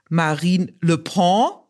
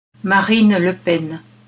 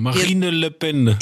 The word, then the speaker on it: Marine Le Pen: Sagte Marine Le Pen und ich Marine Le Pen. Wie uns einige Hörerinnen und Hörer wissen ließen, ist die korrekte Aussprache allerdings so. – Marine Le Pen.